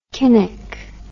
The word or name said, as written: Kinnock